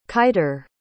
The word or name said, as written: kiter